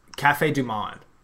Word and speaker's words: Café du Monde: Café du Monde.